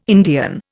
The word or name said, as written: Indian